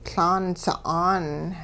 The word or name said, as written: Laan sa'aan